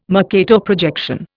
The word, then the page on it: Mercator projection: Indian